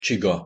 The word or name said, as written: csiga